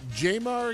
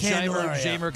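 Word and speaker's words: Jeimer: Is it Jamar again, or is it? – Oh, all right, my Jamer bad. It's Jamer Candelario. – Jamer. – See, I'm actually saying it right now.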